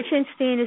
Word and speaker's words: Liechenstein: Liechenstein is spelled LIECHENSTEIN.